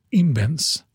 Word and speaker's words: Imbens: Amerikanen Joshua eh, Angrist hade jag inte gissat på och holländaren, ska man uttala det rätt på flamländska, mm. – Guido Imbens, mm. tror jag en ska uttala det.